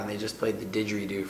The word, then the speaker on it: didgeridoo: It was like another show, the didgeridoo portion of the show.